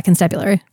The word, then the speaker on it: constabulary: And so they head to the nearest constabulary, which is a great word, and I don't know if I'm saying it right. Maybe you're supposed to emphasize a different syllable. – That's, yeah, constabulary.